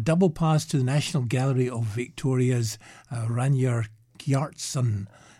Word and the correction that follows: Ragnar Kjartansson: Oh, Ragnar Kjartansson.